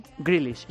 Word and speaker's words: Grealish: Grealish, ¿lo he dicho bien? – Grealish. Sí.